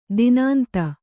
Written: ದಾನಾಂಧ

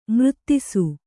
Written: ಮೂರ್ತಿಸು

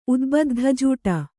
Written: ಉದ್ಬದ್ಧಜೂಟ

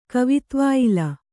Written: ಕವಿತ್ವಾಯಿಲ

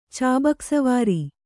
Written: ಚಾಬೂಕಸ್ವಾರ

